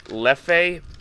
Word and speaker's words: Leffe: Leffe Belgian Ale, Belgian Blonde.